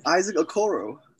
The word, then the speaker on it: Okoro: Uh, Awakawu. – I know. I know he's from Atlanta. It's Isaac Okoro.